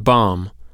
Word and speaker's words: bomb: Okay, take the word bomb. Why the final B, since you don't pronounce it? Why not just B-O-M, like mom and Tom? – Well, what's another word for bomb?